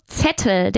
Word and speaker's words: Zettel: Pronunciado Zettel.